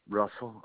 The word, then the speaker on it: Russell: Russell, RUSSELL.